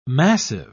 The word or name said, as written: massive